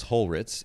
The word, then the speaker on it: Holritz: You know, it's so funny, even with relatively simple names, like my last name is Holritz, H-O-L-R-I-T-Z. Phonetically, it's very simple, but people will still mess it up, even the simpler names.